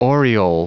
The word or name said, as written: aureole